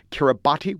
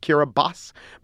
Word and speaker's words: Kiribati: Excellent, excellent flags, as is the flag of Kiribati, which I found out is actually pronounced Kiribati.